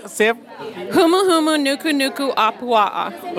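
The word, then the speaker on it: humuhumunukunukuapua'a: Humu humu nuku nuku apua'a.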